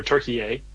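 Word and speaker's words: Türkiye: or Turkey-A, as the proper pronunciation.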